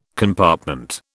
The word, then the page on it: compartment: British English